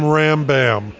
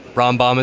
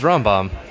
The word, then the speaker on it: Rambam: In Texas, I'm Rambam, as in thank you, ma'am. – Oh, man. – Well, at least it rhymes, but sadly, Rambam is Rambam.